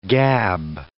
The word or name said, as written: gab